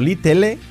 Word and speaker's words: little: Pequeño, little, escrito little, pronunciación, little, little. Little, la L marcada al principio, la la la la la, y luego la L del final, muy sutil.